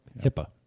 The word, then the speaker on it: HIPAA: HIPAA.